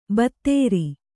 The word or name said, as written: ಬತ್ತೇರಿ